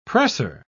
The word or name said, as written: presser